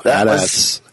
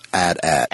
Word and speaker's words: AT-AT: Official from Lucasfilm is, or Lucasfilm Story Group is AT-AT. – AT-AT. – Because they said it's supposed to be pronounced as if you're saying, as if you're rhyming it with hat.